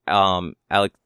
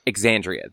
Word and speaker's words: Exandria: Alec, is it? How do you say that? I'm going to say it wrong. The current thing. – Of Critical Role? – Yeah. – Oh, Exandria Unlimited? – Exandria.